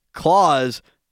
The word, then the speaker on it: Claus: Claus, not Claus. It's Claus, like with a Z, rhymes with pause.